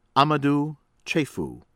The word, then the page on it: Amadou Cheiffou: American English; from Niger